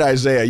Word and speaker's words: Isaias: Isaias is technically pronounced Isaias, which is a Spanish form of the name Isaiah.